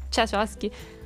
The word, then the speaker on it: Tchaikovsky: Tchaikovsky.